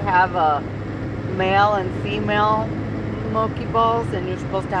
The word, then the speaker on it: Moqui: Moki, and it was spelled just like that sign. – M-O-Q-U-I. Moki.